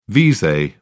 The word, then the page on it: vise: British English